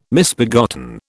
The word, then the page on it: misbegotten: British English